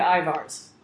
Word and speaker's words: Ivar's: Ivar's, that's where, is that how you say it? Ivar's? – Ivar's.